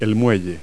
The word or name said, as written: dock